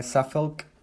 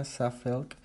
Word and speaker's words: Suffolk: De esta gran canción llega Castle on the Hill, una canción de amor para Suffolk, eh, Suffolk, así, la, se pronuncia, Suffolk, un condado en Inglaterra.